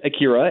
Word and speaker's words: Akira: It's pronounced Akira, A-K-I-R-A, Akira. But don't worry, you're not the first person.